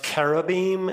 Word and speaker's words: cherubim: Actually, it would be pronounced cherubim. It's a K, but the King James translated it and transliterated it with a CH.